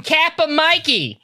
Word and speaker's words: Kappa Mikey: This is, how do you say your name? – Kappa Mikey!